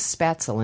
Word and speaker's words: Spätzle: So what is Spätzle, and did I mispronounce that? – Spätzle.